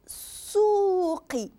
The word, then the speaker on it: السوق: في السوق، شفتوا هنايا السوق، نقزنا من هذا الالف للسين، شفتوا اللام ما نطقتهاش، علاش؟ لانها الشمسيه، والسين قلنا حرف شمسي ماشي حرف قمري.